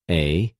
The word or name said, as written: ἠ